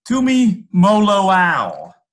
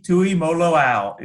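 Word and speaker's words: Tuimoloau: Tumaloau. So Tumaloau. Okay. – Tui. Yeah, JT Tumaloau is how he pronounces that.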